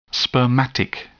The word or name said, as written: spermatic